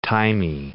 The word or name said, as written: thymy